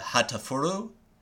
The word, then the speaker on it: Hatoful: Which I believe I'm pronouncing it correctly, of Hatoful, but it could be the Japanese pronunciation of the word, I guess, is more of a Hatafuru.